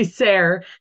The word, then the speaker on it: Sayre: Tracy Sarah. It rhymes with hair.